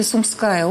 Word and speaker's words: Сумская: Все-таки Сумская область, ударение таковое, только в разговорном варианте Сумщина.